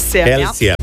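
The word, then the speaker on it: Kelce: Taylor Swift se je petek poročila nogometašem Travisom, a se izgovori Kelsijem, ne? – Kelsijem.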